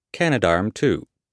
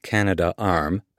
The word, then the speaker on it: Canadarm: The original Canadarms, pronounced Canadarm, not Canada Arm, a pet peeve of Hadfield's, were critical to the assembly and growth of the ISS.